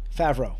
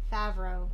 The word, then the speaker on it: Favreau: How do you pronounce his last name? – Favreau. – Favreau. – Yes. – Because I keep wanting to think, I keep thinking of Brett Favre.